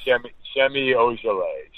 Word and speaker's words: Semi Ojeleye: Is it Semi Ojeleye? Probably way off. – Semi Ojeleye. Semi Ojeleye, transferred from Duke to SMU, known as maybe the best kid in the draft.